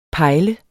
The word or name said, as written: pejle